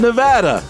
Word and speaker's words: Nevada: Certainly not in Nevada, because apparently I can't pronounce the state properly. – It's Nevada.